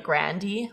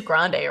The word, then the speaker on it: Grande: Also, apparently, before she got famous, like her parents, like everyone in her family said, like Grandy, like Ariana Grande. But like it is Grande, right? Like that's how you say it.